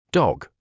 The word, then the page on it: dog: British English